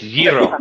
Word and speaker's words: gyro: Sorry, my wife's Greek. It's gyro. It's gyro. Yeah, yeah. That's how you say it.